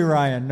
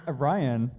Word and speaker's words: Ryan: It's actually Ryan, no B.